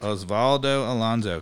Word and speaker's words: Osvaldo Alonso: Osvaldo Alonso. Am I pronouncing that correct? – Osvaldo Alonso, correct, okay.